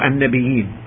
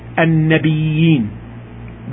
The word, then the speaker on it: النبيين: And النبيين, there are two recitations for النبيين. The first one is, please listen to how it is pronounced, النبيين, with a stressing on the ya, النبيين.